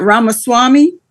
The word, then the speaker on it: Ramaswamy: You got Ramaswamy.